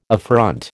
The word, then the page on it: affront: American English